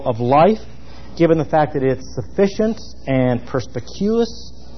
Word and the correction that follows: perspicuous: perspicuous, I threw out a syllable there, in its redemptive revelation, it is also fully authoritative.